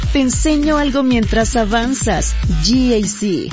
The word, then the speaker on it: GAC: Se escribe GAC, pero se pronuncia GAC. Repítelo, GAC.